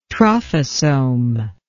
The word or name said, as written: trophosome